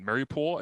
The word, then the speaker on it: Mariupol: But then the next one is, well, there's a theater in Mariupol, I believe is how you said you pronounce it.